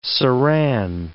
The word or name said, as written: saran